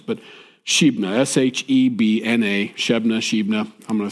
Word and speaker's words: Shebna: His name is Shebna. I think that's how it's pronounced, Shebna. I'm going to choose Shebna because